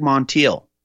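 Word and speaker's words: Monteilh: Craig Montiel, Craig Montiel, that's his name.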